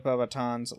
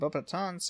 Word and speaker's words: Beauxbatons: In the UK and US audiobook editions of Harry Potter and the Goblet of Fire, the pronunciation of Beauxbatons sounds more French, as both Stephen Fry and Jim Dale respectively not having pronounced the S at the end of the name.